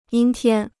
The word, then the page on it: 阴天: yīn tiān